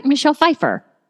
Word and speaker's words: Michelle Pfeiffer: Kelly there and Michelle Pfeiffer. Did I say that right? Michelle Pfeiffer.